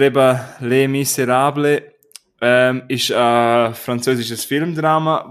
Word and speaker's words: Les Misérables: Aber der Originaltitel ist Le Miserable. – Klingt das ah. gut? Ja. – Habe ich es richtig ausgesprochen? – Das heißt Les Miserables.